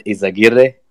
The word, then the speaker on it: Izaguirre: But with us today is John Izaguirre, BNB Chain Director.